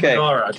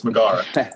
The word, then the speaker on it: Megara: Okay, her name's Megara. Her name's Megara. Sorry, I've been saying it wrong. I've been too friendly. It's Megara.